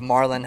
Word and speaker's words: Marlin: It's Marlin. How, I don't know how Americans say it, but it's you roll the R.